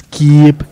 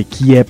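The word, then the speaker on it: Kyiv: Usted sabe que hoy hablamos con un experto que trabajó con el Ministerio de Defensa de Ucrania y nos dijo que Kiev se pronuncia así, en ucraniano es Kiev, porque Kiev es la pronunciación rusa y para ellos es ofensivo. – Es bien ofensivo que uno lo pronuncie Kiev.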